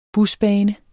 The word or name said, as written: busbane